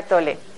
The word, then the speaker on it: Tolle: Eckhart Tolle porque es un nombre alemán en realidad se pronunciaría Tole.